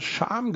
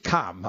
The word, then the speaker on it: Cham: Jetzt wissen wir endlich mal, wie dieser Ort ausgesprochen wird, der sich ja mit Cham schreibt. Und wir Flachland-Tiroler wahrscheinlich immer Scham gesagt haben oder sowas. Oder Cham oder was. Also er spricht sich Cham.